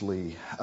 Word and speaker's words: Leigh: The second name is spelled L-E-I-G-H. Pronounced Lee, I'll skip this and say something else.